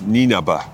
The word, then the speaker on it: Nienaber: I was chatting to Bernard Jackman, and he was chatting to your coach, Jacques Nienaber. I've said that right. – Yeah, Jacques Nienaber.